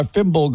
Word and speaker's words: Fimble: Now, it's brought to you by Fimble Garage. Kind of rhymes with thimble, right?